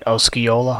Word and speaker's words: Osceola: In addition to being a captain, he was also a fireman, a member of the former Oscola, am I pronouncing that right? Osceola Hose Company, and at one time the chief engineer of the Elizabeth Fire Department.